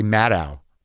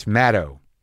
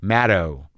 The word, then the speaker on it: Maddow: I think I've been saying her name wrong forever. I say Maddow. Rachel Maddow, but it's Maddow. Like shadow. Maddow.